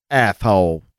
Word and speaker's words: Athol: Sometimes known as, I'm guessing it's Atoll, but it's spelled... – Athol. – A-T-H-O-L. Athol. – That's right.